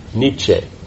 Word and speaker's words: Nietzsche: Nietzsche. – So you say the E. So he's German. – Yes. – He is, okay. Not that we're making a connection between German and Nietzsche.